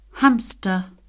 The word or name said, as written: hamster